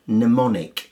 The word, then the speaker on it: mnemonic: Silent L in calf, C-A-L-F, talk, T-A-L-K, and should, S-H-O-U-L-D. – And a silent M. Now, this is a bit unusual, this. If it starts with M-N, then the M is silent. In an unusual word, mnemonic, which means something that you use to remember things.